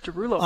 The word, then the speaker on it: Derulo: How do you pronounce Derulo. That? Derulo? – Derulo.